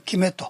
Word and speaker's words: 肌理: でこのキメという字は漢字で書くと、この皮膚の肌ですね、肌に、理科の理、断りっていう字、つまりこの肌理と書いてキメという呼ぶわけです。